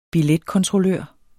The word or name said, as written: billetkontrollør